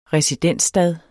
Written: residensstad

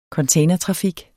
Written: containertrafik

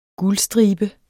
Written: guldstribe

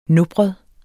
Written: nopret

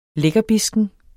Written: lækkerbisken

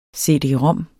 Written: cd-rom

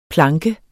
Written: planke

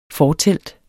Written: fortelt

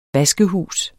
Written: vaskehus

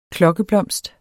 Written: klokkeblomst

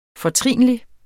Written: FORTRINLIGE

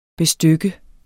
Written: bestykke

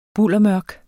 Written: buldermørk

